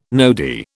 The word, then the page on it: nodi: British English